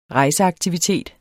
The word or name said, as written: rejseaktivitet